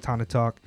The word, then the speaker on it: Tana Talk: Yeah, like Tony Montana. Tony Montana. – Word. – Yeah, I think that's how it's pronounced. Tana Talk.